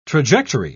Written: trajectory

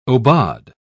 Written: aubade

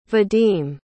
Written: vadim

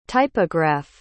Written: typograph

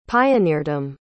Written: pioneerdom

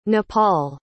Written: nepal

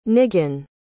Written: Nigan

